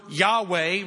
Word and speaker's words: Yahweh: God also said to Moses, say to the Israelites, the Lord, this Hebrew word here is the Hebrew word Yahweh, right? Or that's how we pronounce it, but it's YHWH, right? They've added the Hebrew vowels from the word Adonai, and they've come up with Yahweh.